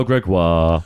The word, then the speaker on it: Gregoire: Bonsoir, je m'appelle Gregoire. I say that in French because it rhymes, it doesn't rhyme in English.